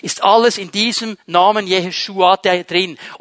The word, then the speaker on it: Jeshua: So wird der Name Jesu ausgesprochen auf Hebräisch. Jeshua.